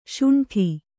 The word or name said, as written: ಶುಂಠಿ